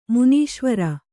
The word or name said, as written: ಮುನೀಶ್ವರ